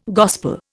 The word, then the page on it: gospel: Indian